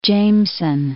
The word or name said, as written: Jameson